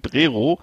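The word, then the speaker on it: Prerow: Regenbogen Camping Prero, geschrieben äh, für Westdeutsche Prerov mit OW am Ende wird aber O ausgesprochen, so wie die ganzen Orte.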